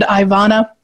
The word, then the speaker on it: Ivana: So, this is my sweet friend, Ivana. Am I saying that correctly? – I love the way you say it.